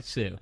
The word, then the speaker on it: Suh: I think it's Ndamukong Suh. – Certainly. – Sue. I'm sorry. – Sue. – That would be the part I messed up.